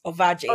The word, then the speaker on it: Ovadje: I mean, it's pretty much the way it's spelled, Ovadje.